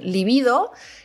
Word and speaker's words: libido: Sí, porque nunca la decimos bien, porque, o sea, libido, a ver cómo se lo digo bien, libido sería la forma correcta de decirla porque no lleva tilde. Es una palabra llana y que se escribe con B, con lo cual tendría que ser eh, libido.